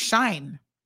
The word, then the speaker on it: Shein: Soka says Shein, we're all pronouncing it wrong, I think, is pronounced like shine.